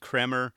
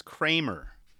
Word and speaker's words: Kremer: You know, I swear, I said Kremer on a previous episode. It's still hard for me to say that. And everyone thinks it's Kramer, like as in Seinfeld.